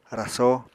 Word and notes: rasó: Apatani

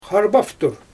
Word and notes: xarbaftur: Xyzyl

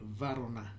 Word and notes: Varona: new form of Eronan

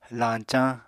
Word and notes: lañcañ: apatani

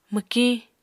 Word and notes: mwkiñ: Apatani

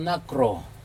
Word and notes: nakro: Aneityum

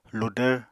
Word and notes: ludvr: Apatani

